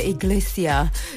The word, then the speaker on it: Iglesias: Trunks FM, Enrique Iglesias, si tú te vas, or Sam has said, Enrique Iglesias, you don't pronounce the S, but I'm going to go international.